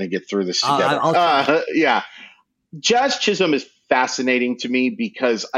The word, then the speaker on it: Jazz Chisholm: No, it's Jazz Chisholm.